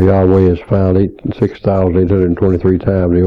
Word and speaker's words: Yahweh: Yahweh, the Tetragrammaton, J-H-V-H or Y-H-W-H, probably pronounced, most people now think Yahweh, but Jehovah's been used so long I hesitate to drop it, and we can't be absolutely sure.